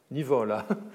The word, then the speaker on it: Nivola: C'est cette figure qu'il évoque dans une lettre à Nivola, à Nivola, pardon, Nivola, accent tonique sur la, pénultième.